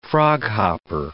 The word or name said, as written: froghopper